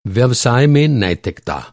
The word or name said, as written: नैतिकता